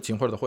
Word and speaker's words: 秦桧: 就是秦桧的桧啊，